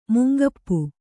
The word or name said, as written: ಮುಂಗಪ್ಪೆ